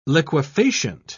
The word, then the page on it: liquefacient: British English